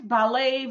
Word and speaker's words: ballet: Ballet в дань французскому языку произносится без ти на конце и с ударением в конце.